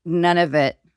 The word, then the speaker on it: Nunavut: But first, Kaitlyn, if I had grown up in a hut in Nunavut territory, which, yes. – Am I saying that correctly? – You are, yes.